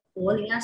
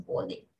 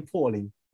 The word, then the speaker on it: 珀臨: 对，然后他他的大致的意思就是讲，就是想要把从各地来的朋友们好好的招待他们，所以我们就用柏林这个名字，因为土产店嘛，对，呃，我们会念成柏林啊，虽然那个字是琥珀的珀啊，可是我们会念成柏林。 – 哦，还是也是，不好意思，念错了，我一直以为是琥珀的珀，所以念珀林，然后英文又叫对，它其实是。